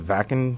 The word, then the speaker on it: Wacken: I heard it's actually pronounced the Vakken Festival. – Vakken. Yeah. – I was pronouncing it wrong all week. – No, well, it looks like it's called Wacken.